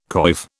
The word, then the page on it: coif: British English